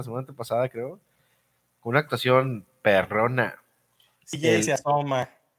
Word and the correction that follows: Uzoma: ¿Cómo se dice bien su, nombre, güey, por favor? – CJ Uzoma. – CJ Uzoma. – Uzoma.